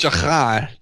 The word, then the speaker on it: Strachan: Owen Strachan, thank you for the uh, thank you for the four part sermon there. – It's pronounced Shachan. Shachan. Shachan.